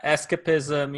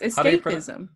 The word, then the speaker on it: escapism: It is, but why did you pronounce it that way? It's escapism. – Escapism. Oh, sorry, I don't know. – It's the ism of escape.